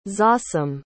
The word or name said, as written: zosime